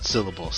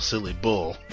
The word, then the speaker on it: Silly Bull: The Silly Bull. Not syllable, Silly Bull.